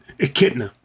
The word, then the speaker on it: Echidna: Now, they had intended for a much larger game with the new character Knuckles, the Echidna, is that how you say it? Echidna? – Echidna. – Echidna. Knuckles the Echidna.